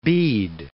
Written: Bede

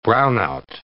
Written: brownout

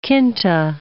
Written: quinta

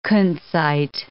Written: kunzite

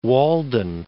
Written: Walden Pond

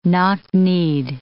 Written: knock-kneed

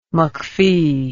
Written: mcfee

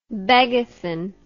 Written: begathon

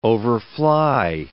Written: overfly

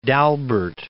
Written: D' Albert

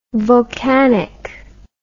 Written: vulcanic

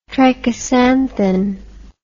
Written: trichosanthin

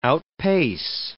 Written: outpace

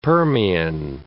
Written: Permian

